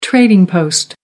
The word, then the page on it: trading post: American English